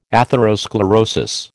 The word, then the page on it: atherosclerosis: American English